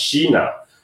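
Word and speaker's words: "shina," (支那): In Japanese, it's pronounced "shina," right?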